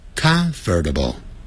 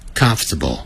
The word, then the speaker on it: comfortable: 别念成 comfortable，相信我，老外就是这样念的，除非他是移民的人还搞不清楚。 native speakers of English，我讲的是在美国或者是加拿大，他们一定是念成 comfortable，相信我。